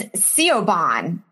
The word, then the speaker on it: Siobhan: Like Siobhan is Siobhan. You know what I mean? Like, it's spelled S-I-O-B-A-H-N.